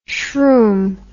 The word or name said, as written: shroom